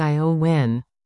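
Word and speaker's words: Éowyn: named Storm Eowyn, pronounced Iowyn, by weather authorities, roared in.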